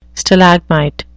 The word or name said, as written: stalagmite